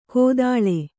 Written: ಗೊದಳೆ

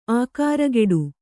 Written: ಆಕಾರಗೆಡು

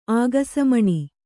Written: ಆಗಸಮಣಿ

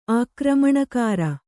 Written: ಆಕ್ರಮಣಕಾರಿ

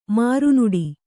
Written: ಮರುನುಡಿ

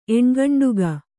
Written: ಎಣ್ಗಂಡುಗ

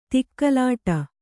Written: ಧಗಲಿಡು

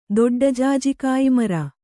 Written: ದೊಡ್ಡಜಾಜಿಕಾಯಿಮರ

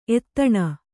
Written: ಎತ್ತಣ

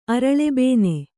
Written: ಅರಳೆಬೇನೆ

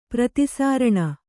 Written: ಪ್ರತಿಸಾರಣ

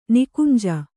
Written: ನಿಕುಂಜ

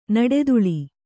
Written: ನಡುತಲೆ

